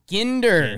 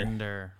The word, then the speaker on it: Ginder: Update, it's Henry Ginder. – Ginder. – Ginder. – Hard G.